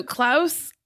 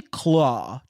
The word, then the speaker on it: Klaw: So Klaus or Klau? Klau? – It's actually Claw. Just like LOL. – It's spelled like a stupid way, but his name is Ulysses Klaw, and in the comics he has like a bionic arm, and that's kind of what you see in the movie.